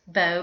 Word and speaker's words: beau: And I looked it up to see what the origin of it was, and it comes from the French word beau, which is pronounced beau, but it's spelled B-E-A-U.